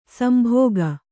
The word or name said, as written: ಸೆಂಬಕ